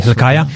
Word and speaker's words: Hilkiah: I, I may not be able to pronounce the name, but uh, Hilkiah or something, Hilkiah, yes, Hilkiah, yeah.